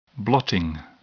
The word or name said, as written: blotting